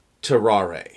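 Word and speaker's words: Tarrare: Now, are we pronouncing it terrare, terrar? Do we know how it's supposed to be pronounced? – I am going by terrare.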